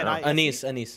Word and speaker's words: anise: How do you pronounce that? Anise? – I don't know. – Anise.